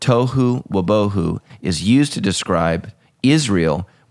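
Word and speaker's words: tohu wobohu (תֹהוּ וָבֹהוּ): I'm not pronouncing it right. We'll come back next week with the correct pronounce. It's like tohu wobohu. There we go. – That's right. – That's the Hebrew. Tohu wobohu. So that's how you say it.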